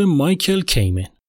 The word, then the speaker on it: مایکل کیمن: مایکل کیمن یادتون بندازم که از ایشون توی قسمت پنجم یعنی قسمت مربوط به بلک آلوم گروه متالیکا شنیدین البته اینطوری نشنیدین چون من اشتباهی اونجا کامنت تلفظش کرده بودم